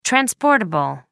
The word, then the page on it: transportable: American English